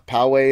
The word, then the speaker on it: Powehi: It's got a name Poway. Now. Yeah, Poway is the name.